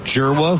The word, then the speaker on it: Dzierwa: Joseph Jirwa, whose last name is D-Z-I-E-R-W-A, pronounced Jirwa.